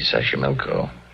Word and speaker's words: Xochimilco: Xochimilco. Okay. So you pronounce it better than I do. – It's easy, Xochimilco.